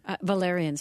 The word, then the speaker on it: valerian: Valerian root? – Valerian, sorry.